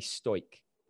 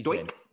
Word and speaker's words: Stoic: The Daily Stoic. I think it's pronounced Stoic. Amazing. – Stoic. – Stoic, like S-T-O-I-C.